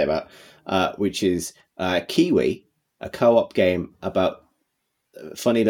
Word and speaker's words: Keywe: It's Kiwi as in the bird, but it's spelt key as in keyboard key, K-E-Y-W-E, we as in us.